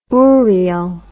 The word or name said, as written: oorial